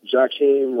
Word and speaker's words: Jacquim: Jakim Ross. Jakim is spelled J-A-C-Q-U-I-M. Last name is Ross, R-O-S-S.